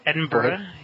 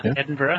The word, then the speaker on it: Edinburgh: We had Edinburgh. Yeah, Edinburgh.